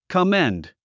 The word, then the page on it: commend: kəménd; コメェンド